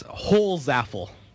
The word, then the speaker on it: Holzapfel: I can confirm it's Holzapfel. Remus was smart enough to pull out Riley Holzapfel. He was smart enough to pull up the NHL pronunciation guide, which I guess I should have on hand a little bit more often.